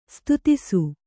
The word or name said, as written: ಸ್ತುತಿಸು